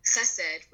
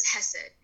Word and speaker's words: Chesed: The same way terms like Shabbat will be pronounced Sabbath by someone who's not Jewish, or, you know, Chesed, which is a Hebrew term for charity work, will be pronounced as Hesed because it's a little harder to pronounce the ch in the native tongue.